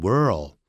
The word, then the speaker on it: world: 顺便讲 world， world 舌尖是卷的，加一个 l， w-o-r 是念成 world，加一个 l 的时候舌尖就跳上去， world，而变成 well， well 搞不清楚这样子啊， world。所以 he chose nothing but 干什么呢？